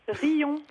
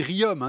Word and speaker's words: Riom: Alors, comment on prononce le nom de votre commune ? – Rion. – On dit Riom hein, quand on se trompe, c'est ça hein ? – Voilà. – Ça voilà. – S'écrit R-I-O-M et c'est dans le Puy-de-Dôme.